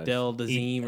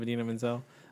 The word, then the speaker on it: Idina Menzel: Adele Dazeem or something. Instead of Idina Menzel.